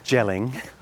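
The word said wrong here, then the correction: Jelling: Dad, it's yelling, Dad. – What do you mean? It's gelling. It's pronounced... It's got a J on it. – But that's not normal. You say Y for Germanic languages.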